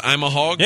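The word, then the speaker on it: Ima Hogg: Is it pronounced I'm a Hog? – Yeah.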